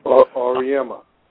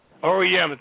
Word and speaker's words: Auriemma: Auriemma. – Auriemma, thank you.